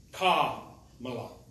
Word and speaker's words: Kamala: Stress on the ka. – Kamala.